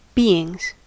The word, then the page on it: beings: American English